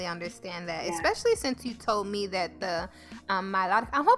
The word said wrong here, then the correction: Nilotica: I hope I'm pronouncing it correctly. You said Milotica? – Nilotica. – Yes. Nilotica. – Yeah, like so, from the Nile.